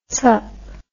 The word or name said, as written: zup